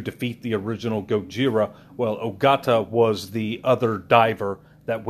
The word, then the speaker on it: Ogata: Like, I know Ogata, you know, is correct, but or Hideto, you know, I'm not too sure.